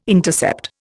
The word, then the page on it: intercept: Indian